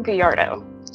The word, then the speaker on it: Gallardo: Kamela Gallardo. – Gallardo. – Gallardo. Rick can say it much better. He rolls his R beautifully, but that's how I, the American, pronounce it.